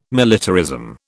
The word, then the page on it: militarism: British English